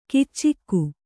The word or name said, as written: ಕೀಚಕ